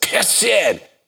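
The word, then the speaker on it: Hesed (חסד): You were talking about Hesed. If you say that properly, and in a COVID totalitarian world, you can't, but it's a Hesed. It's a deep guttural sound.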